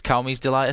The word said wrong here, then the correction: Cayman's Delight: or something like that? – Yeah, Cayman's Delight.